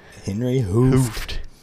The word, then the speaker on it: Hooft: I think it was, he's with Henry Hooft. – Ooh, yes. Oh, extra T. – Yep. The old not silent T. – That's right. Henry Hooft.